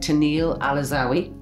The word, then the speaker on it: Tennille Alazzawi: Today I'm sitting here with Tennille Alazzawi.